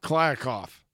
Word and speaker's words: Kliavkoff: Kliavkoff. – Blake Bortles. – K L I A V K O F F. Kliavkoff. Kliavkoff.